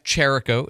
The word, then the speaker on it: Jericho: One more time, it's Jeff Jericho is how you say his name. I've heard it pronounced all kinds of different ways. That's how you say it. Okay, like Jericho, the city with the ch.